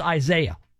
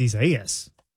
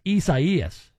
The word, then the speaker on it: Isaias: He says it's pronounced Isaiah. It's not actually. – In Spanish, it's Isaias. – It's Isaias. It's not Isaiah, Mike says. Mike might want to pronounce it that way. That's fine. But I've confirmed it is Isaias.